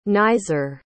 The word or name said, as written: naiser